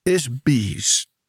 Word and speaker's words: ISBE: Let's begin with the all life forms are ISBEs. IS-BEs. Is that how you say it? – Yeah, IS. I think it's called or pronounced ISBE. – Right. – It's spelled IS-BE.